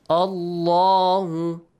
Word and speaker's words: Allahu: The lam in the word Allah will be pronounced with a heavy sound. La. So that's why we pronounce it this way. Allahu.